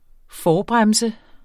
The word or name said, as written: forbremse